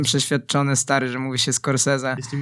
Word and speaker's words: Scorsese: Wymawia się to Martin Scorsese.